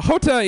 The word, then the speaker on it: Jota: Yeah, so Jota is the letter J in Spanish. It's pronounced with a H, kind of like jalapeno.